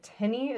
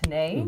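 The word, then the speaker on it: tenné: So that word that looks like tenny is pronounced tenay.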